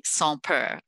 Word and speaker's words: Sans Peur: Please tell us about your company, Sans Peur.